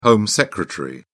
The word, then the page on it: home secretary: British English